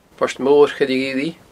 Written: Port Mor Choille Ghillidh

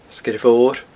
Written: Sgeir Mhor

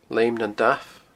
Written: Leum nan Damh

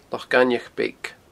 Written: Loch Gainmheach Beag

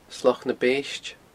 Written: Sloc na Beiste

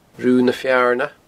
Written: Rubha na Fearna

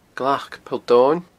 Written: Glac Poll Domhainn

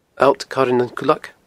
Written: alltcoirenancuileag

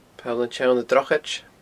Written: Poll an t-Seann Drochaid

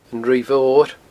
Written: Ruigh Mhor

